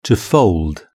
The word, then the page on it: to fold: British English